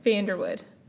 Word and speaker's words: Vanderwoude: Vanderwoude. – Vanderwoude. So you pronounce it Vanderwoude. – I do. It's Dutch.